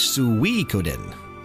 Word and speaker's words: Suikoden: But the official pronunciation is Suikoden. – Yeah, it's a mixture of Japanese and Chinese.